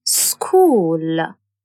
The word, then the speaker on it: school: H is a silent letter in school, mechanic.